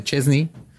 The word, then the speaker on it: Szczęsny: S-Z-E-Z-N-Y. Y es Szczęsny, se pronuncia Szczęsny.